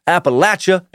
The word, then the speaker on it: Appalachia: all pronounce it as follows. Appalachia and Appalachian.